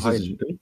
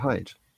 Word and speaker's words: Haidt: Ja, oder wie spricht man den aus? Ich glaube Haidt, oder nicht? – Ja, Haidt. – Ja, und. – Ist der Haidt.